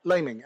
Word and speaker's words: Laming: How do you say your last name, Mark? – Laming. L-A-M-I-N-G. – Well, fuck. You should have corrected. I said Lamming.